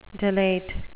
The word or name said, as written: delate